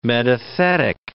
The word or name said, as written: metathetic